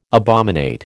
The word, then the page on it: abominate: American English